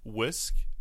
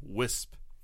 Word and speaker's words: whisk: Well, she spent the longest time pronouncing the kitchen tool whisk as wisp.